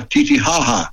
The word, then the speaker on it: Titicaca: In bus siamo andati a Puno, che è la città che si affaccia sul lago di, si scrive Titicaca, ma si pronuncia Titicaca.